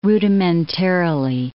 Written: rudimentarily

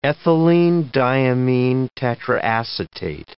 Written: ethylenediaminetetraacetate

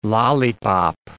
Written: lollypop

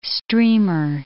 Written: streamer

